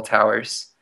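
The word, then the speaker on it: Tow'rs: If you search Towers with an apostrophe instead of an E, so one syllable Towers, we're pretty searchable on Google. So you spell that T-O-W apostrophe R-S.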